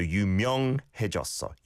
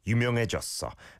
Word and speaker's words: 유명: It almost sounded like 유명. So that's a really common mispronunciation. And I think even Koreans sometimes do it. Instead of the 이응, it sounds like a 니은. So 유명해졌어. 유명해졌어.